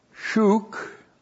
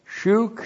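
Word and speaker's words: shuk (שׁוּק): The vats, and then we have shuk. S-H long U-Q. Shuk. Shuk means to be abundant.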